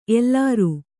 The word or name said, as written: ಎಲರು